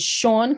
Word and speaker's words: Seagn: My main character, her name is Sean Conway. It's spelled S-E-A-G-N because the mother at the time thought it was Gaelic, and it's really not, but she was trying to be stylish.